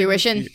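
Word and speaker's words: fruition: Like, this photography stuff's kind of, you know, something we burn the midnight oil to hopefully bring this to how do you say that word? Fuition? – Fuition. Yeah.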